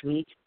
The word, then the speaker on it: Suite: My name is Suite, spelled like the hotel suite, S U I T E, Franchon, F R A N C H O N.